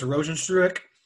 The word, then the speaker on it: Rozenstruik: Jairzinho Biggie Boy Rozenstruik. – Yeah. Yep. You did pretty good. I would have butchered that first name, but I believe it's Rozenstruik.